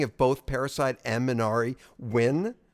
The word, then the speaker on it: Minari: Minari, yeah.